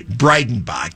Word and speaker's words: Breidenbach: Fortunately, it doesn't end with an injury situation, but authorities say that the man, he's been identified as Dylan, and I'll again pronounce his name carefully, Breidenbach, that's with a D, B-R-E-I-D as in dog, E-N-B-A-C-H, had been harassing customers earlier in the evening and was asked to leave before being escorted out.